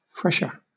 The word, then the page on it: fresher: British English